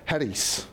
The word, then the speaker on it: charis: Grace, the Greek word, and we say it English-wise, charis, but I actually did some study on the Greek. It's actually pronounced charis, okay?